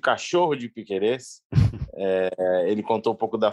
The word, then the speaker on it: Piquerez: Eu até falei pro Thiagão, eu ia mandar uma mensagem pro Thiagão, que agora eu descobri como se pronuncia Piquerez. É realmente Piquerez.